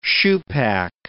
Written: shoepack

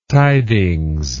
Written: tidings

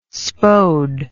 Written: spode

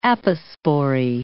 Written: apospory

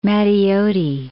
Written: Matteotti

